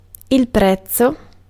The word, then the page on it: worth: /ˈwɜːθ/; /wɜrθ/; /ˈwɝθ/